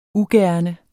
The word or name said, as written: ugerne